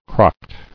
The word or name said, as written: Crocked